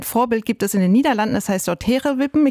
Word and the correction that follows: Tegelwippen: Ich hoffe, ich habe es jetzt richtig ausgesprochen. Mhm. Dort wird tatsächlich schon seit 2021 entsiegelt. – Tegelwippen.